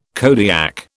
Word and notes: Kodiak: British English

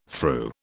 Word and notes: threw: British English

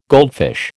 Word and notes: goldfish: American English

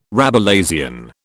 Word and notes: Rabelaisian: British English

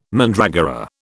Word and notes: mandragora: British English

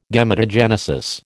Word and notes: Gametogenesis: American English

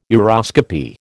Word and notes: uroscopy: American English

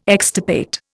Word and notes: extirpate: Indian

